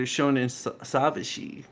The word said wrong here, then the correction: Shonen Sabishi: Which one was it again, guys? – Oh, God, you don't even know the name of our own podcast. – I do know the name, but the pronunciation is important. – It's Shonen Sabishi.